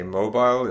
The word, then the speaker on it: mobile: You can say mobile as well for your mobile phone. This is something that students tend to pronounce wrong. – Mobile. – We'd say, yeah, my mobile.